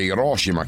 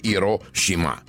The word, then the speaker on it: Hiroshima: Apro una parentesi, c'è chi dice Hiroshima, chi dice Hiroshima, sono sbagliate entrambe le pronunce in realtà, e in giapponese sono due parole separate: Hiro, che significa grande, e Shima, che significa isola, quindi la pronuncia corretta sarebbe Hiroshima.